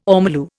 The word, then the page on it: ormolu: Indian